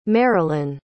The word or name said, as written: Marilyn